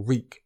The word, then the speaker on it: Reek: You're Reek. Just Reek. It rhymes with freak.